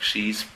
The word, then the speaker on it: she's: She's played. Acuérdense de la pronunciación she's, que es la conjugación de she con has. Y suena como she is, pero es con has.